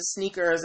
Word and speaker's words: SNKRS: And the app is called Sneakers, but it's spelled S-N-K-R-S, okay? S-N-K-R-S. So Sneakers without any of the vowels.